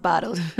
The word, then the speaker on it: BOTLD: B-O-T-L-D, but pronounced Bottled.